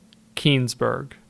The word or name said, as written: Keenzburg